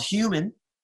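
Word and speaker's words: Huemen: I have a band called Human, mm-hmm. spelled like hue as in color, H U E. That's so confusing. but it's H U E M E N, Hue, but the E's are threes.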